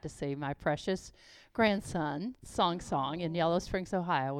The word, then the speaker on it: Cong: His name is spelled in English because it's Chinese, C-O-N-G, so it looks like Kong, but it's pronounced Song, as he would say, Song, Song Song.